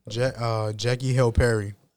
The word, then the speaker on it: Jackie Hill Perry: Jackie Hill Perry.